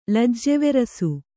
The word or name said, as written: ಲಜ್ಜೆವೆರಸು